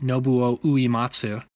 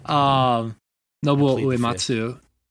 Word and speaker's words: Nobuo Uematsu: as Morgan would pronounce it, Nobuo Uematsu. Nobuo Uematsu. – I feel like even that is probably an extra syllable than he managed, but sure. – Yeah, sure. – I thought I said Nobuo.